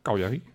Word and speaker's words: Cagliari: Zo spreek je het dus uit? – Cagliari. – Oké. Okay, ja, je dacht Cagliari.